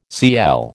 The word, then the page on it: Cl: American English